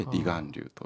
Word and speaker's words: 離岸流: いろんな要因がありますので、で、その中でも今お伝えした離岸流っていうのは、岸を離れる流れと書いて離岸流と読むんですね。で、この岸から沖に向かって流れる強い流れで、怖いのは流されていることに気づきにくいことなんです。私自身も離岸流